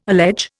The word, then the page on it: allege: Indian